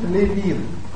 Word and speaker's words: Левин: Курт Левин. Ударение именно Левин.